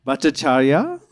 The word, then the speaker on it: Bhattacharya: Bhattacharya? Oh, there you go.